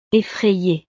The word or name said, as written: effrayee